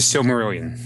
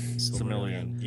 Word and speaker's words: Silmarillion: Give it The Silmarillion. – Silmarillion.